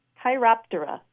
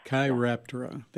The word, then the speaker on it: Chiroptera: Chiroptera. Think of the word chiropractor. Chiropractor, Chiroptera. – Chiroptera.